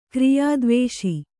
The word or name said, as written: ಕ್ರಿಯಾದ್ವೇಷ